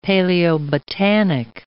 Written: paleobotanic

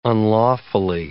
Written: unlawfully

